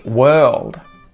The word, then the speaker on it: world: Number 14, world. World. Again, I'm not pronouncing that R. World. It sounds like world, as in you whirl something around.